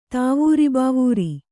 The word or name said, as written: ತಾವೂರಿಬಾವೂರಿ